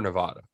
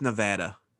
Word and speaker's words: Nevada: It's literally on the other side of Nevada. It's like next to Sacramento. – Oh, okay. Northern California, Northern Nevada. Yeah. And wait, we have to also agree. Are we Nevada or Nevada? – It's Nevada.